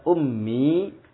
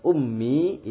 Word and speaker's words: ummi: Hanya pengucapan ummi itu ada tasdid sebenarnya di situ. Di huruf mimnya itu ada tasdid. Zahabat ummi ilal mustasyfa, ya. Al-um itu mimnya ditasdidkan, jadi zahabat ummi ilal mustasyfa, ya.